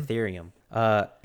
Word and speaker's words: Ethereum: Ethereum. Is their block time and their smart features or contracts, depending on who you talk to. Bitcoin has a block time about 10 minutes, and Ethereum has about 12 to 15 seconds for their block time.